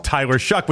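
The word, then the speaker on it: Tyler Shough: It's like they do it every way but Tyler Shuck, which makes sense because it shouldn't be pronounced Tyler Shuck.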